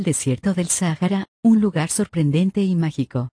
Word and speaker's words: Sahara: La palabra Sahara viene del árabe y significa desierto, la pronunciación más parecida en español sería Sahara.